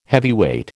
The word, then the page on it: heavyweight: American English